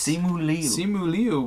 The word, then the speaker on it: Simu Liu: Simu Liu will appear on the next issue.